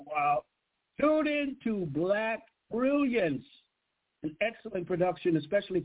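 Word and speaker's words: Blaq: Go to Black, however, it's spelled B-L-A-Q. B-L-A-Q, Black. Brilliance, B-R-I-L-L-A-N-C-E, on YouTube.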